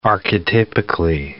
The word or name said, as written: archetypically